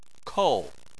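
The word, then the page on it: kou: tone 4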